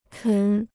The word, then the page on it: 啃: kěn